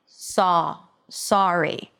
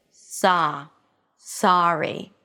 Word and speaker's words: sorry: Yeah, and some people may pronounce that as a more rounded sound. Saw, sorry. But it is not a pure O as many non-native speakers pronounce it. It's not sorry, it's sa, sorry. You can actually visualize this word written with two A's rather than an O.